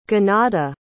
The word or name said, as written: Gyanada